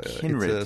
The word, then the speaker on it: Cynred: Is it Cynred of Gwent? Do I have that right? – Kinred with a, Kinred. It's spelled with a C, but a hard K sound.